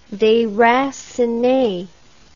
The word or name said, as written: deracine